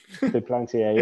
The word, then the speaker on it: Duplantier: I can't pronounce his Duplantier. – Second name good enough. Duplantier, yeah.